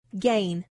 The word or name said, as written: gain